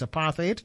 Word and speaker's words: apartheid: I'm told that the correct pronunciation is apartheid, A-P-A-R-T-H-E-I-D, but I know many people commonly say apartheid.